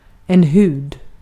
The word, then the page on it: hud: /hʉːd/